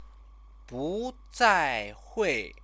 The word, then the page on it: buzaihui: chinese